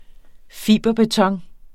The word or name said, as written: fiberbeton